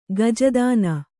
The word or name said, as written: ಗಜದಾನ